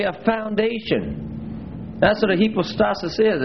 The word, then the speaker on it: hypostasis: the Greek term there is hypostasis.